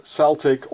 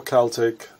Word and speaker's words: Celtic: Well, actually, you can say both Celtic or Celtic. It's the same thing. It's both accepted officially. Mostly in Britain or in Scotland, you know, we say Celtic, and the Americas and the it's being pronounced Celtic. And Celtic, of course, is the more Latin way of pronouncing it.